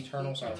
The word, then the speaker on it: Eternals: the third volume of The Externals. – The Eternals. I'm looking right at it, and I mispronounced it.